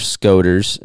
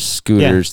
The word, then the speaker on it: scoter: Some surf scoters, if I believe that's how you say it. There's scooters. Yeah. That's what scoter. – Darren kept saying scooter.